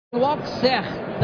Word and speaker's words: Auxerre: Auxerre da França.